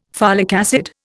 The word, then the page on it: folic acid: Indian